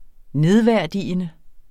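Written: nedværdigende

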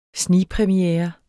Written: snigpremiere

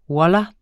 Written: wallah

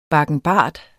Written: bakkenbart

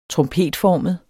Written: trompetformet

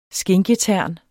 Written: skinketern

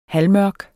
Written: halvmørk

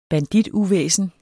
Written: bandituvæsen

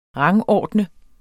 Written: rangordne

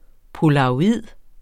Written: polaroid®

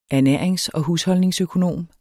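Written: ernærings-

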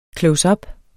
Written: CLOSEUP

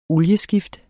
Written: olieskift

